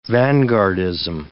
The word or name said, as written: vanguardism